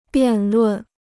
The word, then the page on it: 辩论: biàn lùn